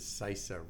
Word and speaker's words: CISA: You know, it's not just because CISA rhymes with FISA.